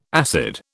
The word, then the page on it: acid: British English